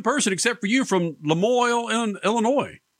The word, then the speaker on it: Lamoille: How do you pronounce, is it Lemoyle, Illinois? How do you pronounce it? – Lamoille, Illinois. Oh, Jesus. Yeah. – I tried to go French, and it's as damn as American as you.